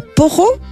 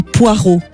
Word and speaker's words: poireau: Un poro ou un poireau? – Poireau. – Poireau. – Ah, le poro. – Mais nous on dit du poireau, hein, parce qu'on a évolué quand même. – Mais on pourrait dire c'est le poireau. – Alors, on le prononce quand on le dit poireau, mais on peut le dire à la romande le poireau.